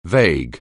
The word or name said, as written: vague